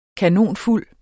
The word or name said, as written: kanonfuld